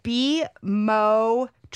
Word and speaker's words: bimotrizinol: It's bimotrizinol. You emphasize the mo.